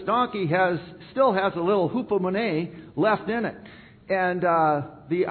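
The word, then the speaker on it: hupomone (ὑπομονή): And hupomone. That is the pronunciation of this particular word.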